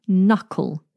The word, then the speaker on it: knuckle: Knuckle. This starts with the letter K, but that is silent. Ah, how useful our English language is. So, knuckle has an N sound, but starts with a K.